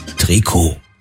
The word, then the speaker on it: Trikot: Jahrhundert setzte sich der Begriff für eng anliegende Sportkleidung durch, weil diese eben aus dem dehnbaren Strickstoff hergestellt wurde und weil im Französischen in der Regel die letzte Silbe betont wird, heißt es richtig Trikot.